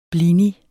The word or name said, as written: blini